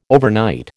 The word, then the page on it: overnight: American English